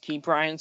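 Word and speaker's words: Ke'Bryan: Now for the Pittsburgh Pirates, I think their best rookie has been Brian Hayes. or I think it's actually pronounced Key Brian, so I'll keep in mind that.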